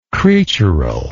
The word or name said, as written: creatural